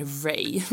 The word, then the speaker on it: Rea: Stavas Rea, vilket är taskigt när man bor i Sverige tycker jag, men man uttalar det Ray.